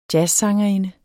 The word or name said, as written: jazzsangerinde